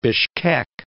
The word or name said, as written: Bishkek